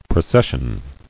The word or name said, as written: Processioning